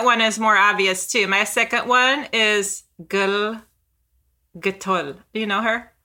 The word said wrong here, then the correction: Gal Gadot: Gal Gadot.